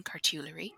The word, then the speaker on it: cartulary: Um, it it gets complicated because um, there are many volumes around today that scholars would refer to today as cartularies, or if we want to use the, the British pronunciation, cartulary,